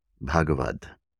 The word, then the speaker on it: Bhagavad: Bhagavad is spelled B-H-A-G-A-V-A-D. Bhagavad, Bhagavad, Bhagavad.